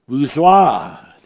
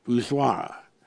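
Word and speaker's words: voussoir: which is the vuzwa that we are talking about, the vuzwa. Now that is spelled V-O-U-S-S-O-I-R. V-O-U-S-S-O-I-R. I believe it's a French word, vuzwa.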